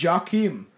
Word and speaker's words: Joachim: No, it is. It's literally pronounced Joachim.